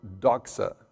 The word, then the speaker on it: doxa: And it's the word doxa. That's how you pronounce it.